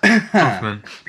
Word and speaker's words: Kaufman: For those of you who don't know Charlie Kaufman, you should, or Kaufman, however it's pronounced. – Kaufman.